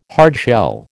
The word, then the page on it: hardshell: American English